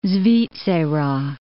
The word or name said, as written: Svizzera